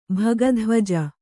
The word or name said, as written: ಭಗಧ್ವಜ